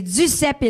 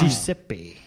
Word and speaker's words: Duceppe: Mais heureusement, ils nous reviennent la saison prochaine chez Duceppe. Ils ont été repris, on le rappelle. – Je pense qu'on dit Duceppe. – Excusez-moi, j'ai mal prononcé.